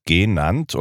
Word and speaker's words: genannt: Hans Sachs hatte noch genannt und bedarf auf der ersten Silbe betont.